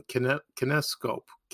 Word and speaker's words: kinescope: Back then in the golden age of television, when a show aired, it never was repeated, and it was on kinescope, That's how you pronounce it.